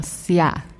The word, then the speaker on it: Sya: Sia or Sia, yeah. It's spelled S-Y-A, and it's by Iza Bagayogo.